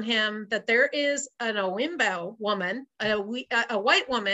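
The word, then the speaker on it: Oyinbo: So anybody who's not black is called Owibo or Owinbo. That's how they pronounce it, Owinbo.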